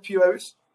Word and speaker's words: Piraeus: So can I pronounce like that, Piraeus? – Yeah, sure. It's correct.